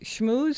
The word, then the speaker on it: schmooze: Schmooze spelled like school. S-C-H-M-O-O like the cow, Z-E.